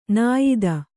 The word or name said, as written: ನಿಯತಿ